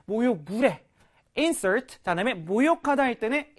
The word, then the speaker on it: insert: 자, insert. 발음이 두 가지야. 명사일 때는 insert, 동사일 때는 insert 가시면 되는데요. 자, 내가 널 insert 하려고 동사죠.